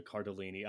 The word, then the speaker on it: Cardellini: Bold of you to assume that I have not lived my life based on the model of Linda Cardellini. I did not say her name right, did I? – No, I think you got it.